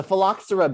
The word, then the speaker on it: phylloxera: The the phylloxera beetle. I listened to this pronunciation 158 times before this goddamn podcast. You give me three sips of whiskey and I'm like beetle. – Yes, the phylloxera beetle, phylloxera, that's what it is, beetle destroyed wine and cognac production in France.